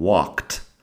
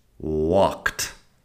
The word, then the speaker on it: walked: Luego cuando pronunciamos el pasado, después del sonido de una K, la forma con ed suena como si fuera una T y nada más. Así tenemos worked and walked. Worked, walked.